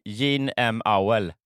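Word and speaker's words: Jean M. Auel: Så Sean, nu uttalade jag det på, som jag gjorde när jag var liten. Men Gene M. Auel.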